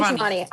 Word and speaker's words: Schiavone: Tony Schiavone.